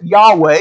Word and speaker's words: Yahweh: It's in the Hebrew, we pronounce it Yahweh.